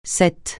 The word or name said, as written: ce